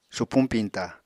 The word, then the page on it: supuñ piñta: apatani